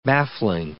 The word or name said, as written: baffling